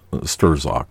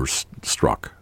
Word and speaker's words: Strzok: And I've pronounced this gentleman's name as Sturzok, but I guess the Z is silent, so it's Peter Strzok. Strzok.